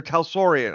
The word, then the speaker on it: Talsorian: I asked how Art Hazelon got its name. – Oh, yes. – Or Talsorian. I'm sorry. I always say it wrong.